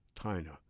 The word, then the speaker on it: Tina: spelled T-I-N-A, but pronounced Tina.